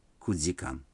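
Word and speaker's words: 九時間: Некоторые цифры в этих случаях произносятся не так, как обычно. Четыре часа – это йодикан, семь часов – ситидикан, а девять часов – кудзикан.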